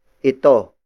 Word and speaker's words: ito: The first pronoun under this category is ito. Ito. Ito. Ito is spelled as I-T-O.